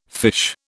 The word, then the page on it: fiche: British English